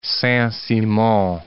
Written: Saint-Simon

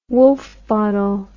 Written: Woulfe bottle

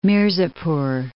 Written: Mirzapur

